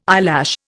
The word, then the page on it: eyelash: Indian